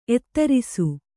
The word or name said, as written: ಎದುರಿಸು